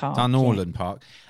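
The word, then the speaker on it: Dunorlan Park: Dunloran Park.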